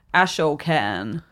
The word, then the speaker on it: Ashokan: And I don't know if this was the same reservoir, the Ashokan, and I might have mispronounced that. Ashokan. – That'll be it.